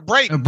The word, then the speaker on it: Braden: Keanu Neal and Brendan Fehoko. – Is it Brendan or Braden? – Braden. I think I'm saying that incorrectly. Braden. Oh, okay. It's spelled a little bit weird there.